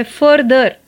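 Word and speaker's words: further: दूसरा जो शब्द है वो है फर्दर फर्दर F U R T H E R फर्दर इसका प्रोनाउंसिएशन भी अलग है और स्पेलिंग भी फर्दर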